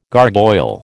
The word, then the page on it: gargoyle: American English